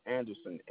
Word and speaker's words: Anderson: That's spelled S-E-A-R-C-Y. Last name is Anderson, A-N-D-E-R-S-O-N.